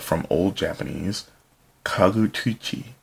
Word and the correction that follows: Kagutsuchi: pardon, Kagutsuchi, also known as Hi no Kagutsuchi or Homusubi, among other names, is the god of fire in classical Japanese mythology.